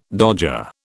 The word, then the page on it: dodger: British English